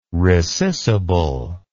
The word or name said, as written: rescissible